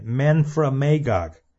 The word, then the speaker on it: Memphremagog: It's called Lake Memphremagog. Let me say that again. Memphremagog. It's spelled M-E-M-P-H-R-E-M-A-G-O-G, Memphremagog.